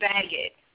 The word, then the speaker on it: Baggett: I'm Bridget Baggett. – You spell the last name, will you? – That's B-A-G-G-E-T-T.